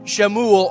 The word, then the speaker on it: Shemuel: The son of Shemuel, or pronunciation, our pronunciation would be Samuel, the prophet.